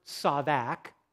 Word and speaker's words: tsadaq: The reason we're looking at it is because we're asking ourselves, what is this word tsadak? That's how you pronounce it, T-S-A-D-A-Q.